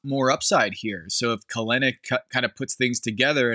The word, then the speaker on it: Kelenic: Kelenic, key of the 140 batting average.